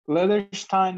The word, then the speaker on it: Leatherstein: Yeah, Leatherstein. That's how I say it, but it's the personal brand, so it both yeah.